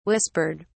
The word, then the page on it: whispered: British English